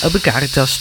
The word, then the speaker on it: Ubi Caritas: Ubi Caritas, als ik het goed uitspreek. – En Ubi Caritas. – Ubi Caritas, natuurlijk. De klemtoon ligt anders.